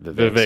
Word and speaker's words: Vivek: Vivek. Sorry. If we're going to do 20 minutes on Vivek, you better be pronouncing his name right.